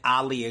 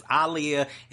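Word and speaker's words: Aaliyah: And I, yes, I said Aliyah because that is the way her name is pronounced. It is Aliyah.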